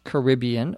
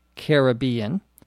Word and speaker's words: Caribbean: You can pronounce it either way in American English Caribbean or Caribbean.